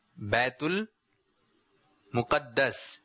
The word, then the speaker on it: بیت المقدس: بیت المقدس. کچھ لوگ کہتے ہیں بیت المقدس، تو یہ تلفظ جو ہے یہ صحیح نہیں ہے، بلکہ درست تلفظ کیا ہے؟ بیت المقدس.